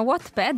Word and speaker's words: Wattpad: Una fra tutti, proprio per la scrittura, è un colosso come Wattpad. L'ho detto giusto, Anina? – Sì, esatto. Si chiama Wattpad ed è prima di tutto un'app gratuita che permette di leggere, sì, gratuitamente, un numero elevato di ebook.